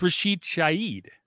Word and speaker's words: Shaheed: I think it's Rashid Shaheed. – Really? – Like the H is silent.